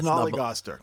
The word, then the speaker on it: snollygoster: Snollygoster. – Snollygoster.